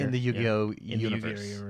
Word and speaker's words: Yu-Gi-Oh!: Yu-Gi-Oh!